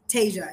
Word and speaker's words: Tasia: Tasia. It's just Asia with a T in front of it.